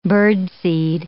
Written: birdseed

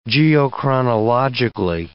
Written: geochronologically

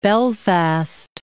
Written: Belfast

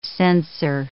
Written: censor

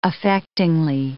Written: affectingly

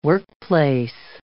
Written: workplace

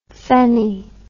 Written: feni

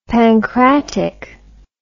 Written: pancratic

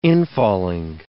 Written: infalling

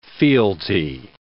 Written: fealty

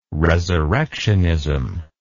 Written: resurrectionism

